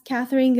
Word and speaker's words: Catherine: Yeah, so I'm as Catherine Gairard. Everywhere, so it's spelled C-A-T-H-E-R-I-N-E. And then my last name is G-A-I-R-A-R-D.